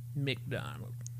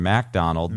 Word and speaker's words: MacDonald: McDonald. Norm MacDonald. – Mac. – That's how he pronounces it.